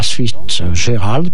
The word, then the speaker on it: Fitzgerald: C'était donc Tease Get a Tasket, chanté par Ella Fitzgerald, pardon.